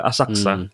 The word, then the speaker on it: Asakusa: I mean, the way to pronounce it, it's kind of a it's kind of silent. Yeah, it's like Asakusa.